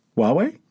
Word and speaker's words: Huawei: We also learned how to pronounce Huawei. – Huawei? – Yeah.